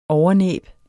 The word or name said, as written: overnæb